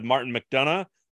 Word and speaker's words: McDonagh: She has a lot of experience, clearly a close relationship with Martin McDonagh.